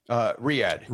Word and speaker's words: Riyadh: Is it Ridya? – Riyadh. – Riyadh, okay. I was looking at it phonetically.